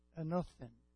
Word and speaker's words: anothen: The word for above is the Greek word anothen. I'm pronouncing it correctly.